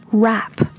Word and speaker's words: wrap: So we started with the word wrap, to wrap up something. Wrap, a, W-R-A-P, wrap, 它的元音是 a 的音 a, wrap. And now we're talking about rip, rip, R-I-P, rip, 它的元音是 i, i 和 a, 你可以听得出来它们是不一样的吗？So, rip off and wrap up.